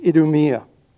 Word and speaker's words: Idumea: I think it's Idumea. I looked it up and I hit the pronounce and it said it multiple times, but repetition penetrates the dullest of minds. I think it's pronounced Idumea. And the regions across Jordan and around Tyre and Sidon.